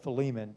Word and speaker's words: Philemon: Now, one thing I do want to say that Brother Dobbins, right before church, he has an app on his phone, and they do pronounce it Philemon. So there are three different ways to pronounce but I'm going to call it Philemon tonight, so that's going to be the pronunciation.